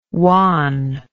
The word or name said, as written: juan